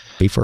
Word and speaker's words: Bifur: is it Bifur or Bifur? I never know. – Before. I don't know the pronunciation of that one either. I'm ashamed to admit. – Before and Bofur. – I usually just say Bifur, but yeah.